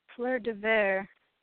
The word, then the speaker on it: Fleur de Verre: Fleur de... – V-E-R-R-E. – Fleur de Ver. – There we go. You at least pronounced it right.